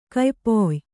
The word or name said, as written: ಕಯ್ಪೊಯ್